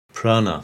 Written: Prana

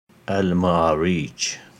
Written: Al-Ma'arij

